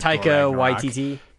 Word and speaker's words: Taika Waititi: That vampire documentary by the dude whose name I can't pronounce who did Taika Waititi.